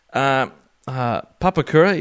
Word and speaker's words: Papakura: Papruka pipe bands, probably butchering that name. Papakura, even. Yep.